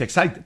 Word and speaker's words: Exide: Se escribe Exide, se pronuncia Exide, decía el comercial de las baterías.